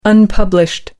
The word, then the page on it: unpublished: American English